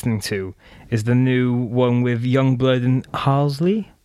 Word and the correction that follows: Halsey: Hol- Halsey. Halsey. Everyone, well, everyone pronounced it wrong.